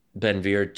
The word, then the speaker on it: Ben Gvir: Okay, so I was talking to a friend who follows Israeli politics closely, and I compared Ben Gvir to, am I pronouncing that right, by the way? – Ben Gvir, yeah.